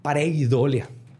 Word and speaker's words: pareidolia: Y la, y la neta, el 90% de las caras es paredoleo. Ajá. Pareidolia. Se me, se me, olvida pronunciar esa I. La I. Pareidolia.